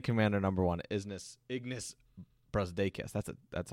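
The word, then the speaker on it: Brazdeikis: Basketball Reference has the phonetic spelling as Ignas Brusdakis. So it's Brusdakis. So Ignas Brusdakis.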